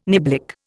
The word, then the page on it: niblick: Indian